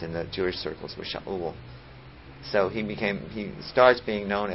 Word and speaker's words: Shaul: His real name was Shaul, and Shaul was a bit of a problem to Greek speakers, that they had a hard time pronouncing that. First of all, there was no consonant in Greek, so that's how we get Saul.